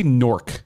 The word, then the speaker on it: Newark: And he, by the way, he does say Newark. It's like a weird word. He doesn't say Newark. He says Newark. – I can say whatever I want. – You say Newark. – You say like N-O-R-K.